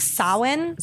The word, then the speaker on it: Samhain: We'll go back a second, but like Samhain, or not, I always want to say Samhain because that's what it looks like. – Yeah. – But it's actually pronounced like Samhain.